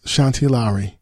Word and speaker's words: Shanti Lowry: And we're talking about the new series, Bronx SIU, Special Investigative Unit, starring Brian White and Shanti Lowry. Make sure I said her name right. Yes, Shanti Lowry.